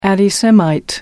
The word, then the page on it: anti semite: British English